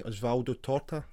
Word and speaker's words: Osvaldo Torta: This was written by Gil Page and drawn by Osvaldo Torta, if I pronounced those names correctly. – Yes, that's right.